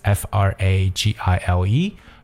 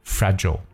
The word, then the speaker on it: fragile: 接下来这个单词跟大家分享的呢，叫 fragility，fragility，f r a g i l i t y，fragility 这个词呢，实际上是来自它的形容词 fragile or fragile，那两种读音呢，英国人呢可以读成 fragile，that's f r a g i l e，而美国人呢会把 i l e 结尾的词呢读成短音 l 的感觉，所以读成 fragile，fragile。 It means like easily broken or damaged，它就表示易碎的或者说容易受损的意思。 Fragile，它的名词是 fragility，就是我们所说的一种脆弱的感觉。